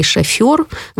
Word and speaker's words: шофер: потому что если буква «ё» есть в этом слове, то ударение будет обязательно падать именно на эту букву. Поэтому такие слова, как «договоренность», «свекла» и «шофер» вообще не должны, по идее, вызывать у нас никаких разногласий.